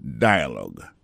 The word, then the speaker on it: dialogue: Dialogue. L'écrit exactement même genre français, D-I-A-L-O-G-U-E, mais américain, on prononce dialogue.